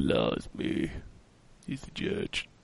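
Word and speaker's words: Jydge: Judge. It's called Judge, but it's spelled Jige. – What? – It's J Y D G E. – Is this still pronounced Judge? Then? – Yeah, it's pronounced Judge.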